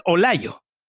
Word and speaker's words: Olallo: It's pronounced Olayo, but I guess it'd be easier for you to write it or spell it as O-L-A-L-L-O, Olalo.